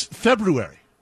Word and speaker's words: February: It's February. – Yeah, you almost over-announce the R, though. So to most of us, it's almost, it's not a silent R, but it's barely a pronounce.